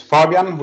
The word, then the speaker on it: Fabian: So my name is Fabian, with a more Swedish pronunciation,